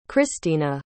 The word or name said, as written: xristina